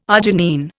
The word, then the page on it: arginine: Indian